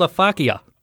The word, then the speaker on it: aphakia: It's called a fakia.